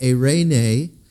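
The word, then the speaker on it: eirene (εἰρήνη): If you go over into the New Testament, the word is pronounced eirene, and it means quietness, oneness, wholeness, harmony or accord.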